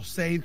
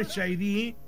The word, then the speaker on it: Sade: Quiero una entrada, por favor, y algo de Shady, o Seid, como le dicen algunos, ¿cierto? ¿Cierto? Shady. Sade, sí, DJ, Sade, sí, para que... ¿Ah? No le digo Sade a usted, no. Se dice Shady, pero se escribe Sade, ¿ah?